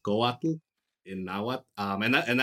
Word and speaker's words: Coatl: That TL sound tends to throw people off, but it's okay if you pronounce it as Coatl, with like almost like you're saying waddle. That's fine, but if you wanted it to be more accurate, it'd be Coatl.